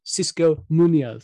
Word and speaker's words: Xisco Munoz: However, another big story this week was, of course, the first sacking in the Premier League of Xisco Munoz. Is that good pronunciation, Oscar? – Beautifully articulated.